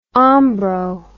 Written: ombro

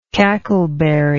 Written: cackleberry

